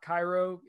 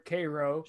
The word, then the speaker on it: Cairo: Well, this time I went a little west to a place called Cairo, not Cairo. It's pronounced there Cairo.